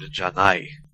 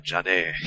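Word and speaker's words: Janai (じゃない): In the standard pronunciation, based on the Tokyo dialect, it would be Ore and Janai. – Right. – Which become, in Goku's dialect, Ora and Jane.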